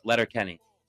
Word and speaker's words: Letterkenny: Like, wait, am I saying it right? – Yeah, it is. Letterkenny.